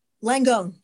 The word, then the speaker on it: Langone: Langone. You were close.